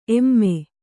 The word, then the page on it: ಏಮ್: ēm